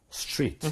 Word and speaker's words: street: Yeah, TR이기 때문에 약간 치읓 발음 조금 넣어주면 좋죠. – 그렇죠. 오케이. 자, 부드럽게 한번 블렌드해서 스트릿을 두번 발음해 드리도록 할게요. 스트리트. 스트리트. – Street, 음흠.